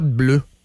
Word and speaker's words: bleuh: J'adore le pot bleu. Pourquoi? C'est quoi le pot bleu? B-L-E-U-H, le H muet à la fin, bien entendu.